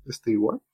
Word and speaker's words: Stewart: El fin es que le rompió el ojo a, a Isaiah Stewart, Stewart. Entonces armó un pequeño problema y... – ¡Wow! Muy uh. bien, se sacó el apellido. Muy bien, muy, muy bien pronunciado el apellido.